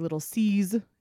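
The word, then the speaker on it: Lil' Cease: And I say little C's, it's spelled little Cease, but it's short for little Caesar.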